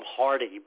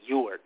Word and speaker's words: Huarte: A lot of people mispronounce his name. They call him Hardy, but his name was actually Ewart, H-U-A-R-T-E.